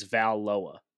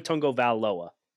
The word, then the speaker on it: Tagovailoa: Because I say Vailoa, and they're like, nah, it's Valoa. So Tua Tagovailoa.